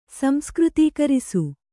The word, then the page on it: ಸಂಸ್ಕೃತೀಕರಿಸು: samskřtīkarisu